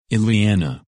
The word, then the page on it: Eliana: English